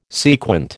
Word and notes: sequent: American English

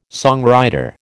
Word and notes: songwriter: American English

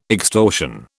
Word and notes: extortion: British English

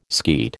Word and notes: skeet: American English

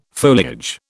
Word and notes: foliage: British English

